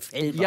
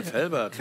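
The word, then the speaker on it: Velbert: Es gibt aber so viele Leute sprechen das falsch aus, weil wir auf der anderen Rheinseite sind, die denken, das ist schon so weit an Frankreich, dass man das... – Felbert? – Ja. Ja. So viele Leute wollen das immer so aussprechen, deswegen, ja. Du hast das genau richtig ausgesprochen.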